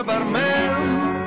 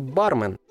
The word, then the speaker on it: бармен: Братишка бармен, налей немного текилы. Она меня так убила. – Но правильно все-таки бармен. Нужно посвятить этому замечательному человеку еще пару песен с правильным ударением, и тогда все заговорят грамотно.